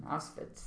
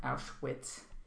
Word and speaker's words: Auschwitz: That's how you say it in German, Auschwitz, not Auschwitz, or however, I forgot how they say it in Americanese.